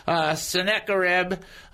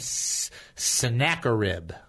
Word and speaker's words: Sennacherib: Sennacherib. That's how you say his name. Sennacherib.